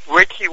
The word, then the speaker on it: Richie: Richie Randall. When you spell that, it's R-I-C-H-R-I-C-H. No, R-I-C-H-I-E, and then R-A-A-N-G-A-L-L.